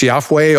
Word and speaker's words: Yahweh: That's, that's where we get the many, many would say Jehovah, right? I think it's probably rightly pronounced Yahweh, although we can't uh, be fully sure.